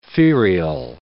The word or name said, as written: ferial